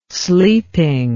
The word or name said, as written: sleeping